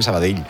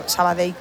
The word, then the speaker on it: Sabadell: El cachondo de Kiki Lesa dice, pronuncias muy bien Sabadell. Dice, mis respetos. Sabadell. – A mí me lo decían mucho cuando estaba en Primera Federación, porque yo también decía Sabadell, que es como se dice.